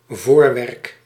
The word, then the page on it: voorwerk: voor·werk